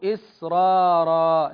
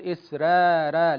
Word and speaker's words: إسرارا: لأن الراء الثانية مفخمة، ففخمت الأولى لأجل الثانية، لأجل الثانية حتى يحصل تعادل في النطق، فنقول إسرارا لا نقول إسرارا، لأن الراء الثانية مجمع على تفخيمها.